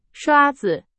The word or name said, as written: 刷子